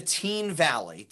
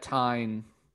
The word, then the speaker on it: Teign: It's I live near the Teen Valley. TEIGN. Teen Valley. – Mm-hmm. – By Teen Village. – I would have pronounced that Tyne.